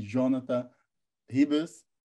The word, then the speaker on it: Jonata Ribas: Estamos aqui com o grande Jonathan Ribas, falei certo? – Isso aí, meu.